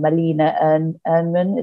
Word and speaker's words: Malena Ernman: I'm going to say her name wrong. Malena Ernman. Is that how you say her name? – I will say yes.